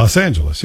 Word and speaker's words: Los Angeles: That's Los Angeles, yeah.